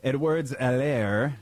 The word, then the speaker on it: Edwards-Helaire: 46 carries for their variety of backs, including Edwards-Helaire, the H is silent.